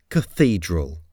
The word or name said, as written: cathedral